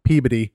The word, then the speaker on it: Peabody: It's spelled like Peabody, but they pronounce everything goofy up there. So Peabody.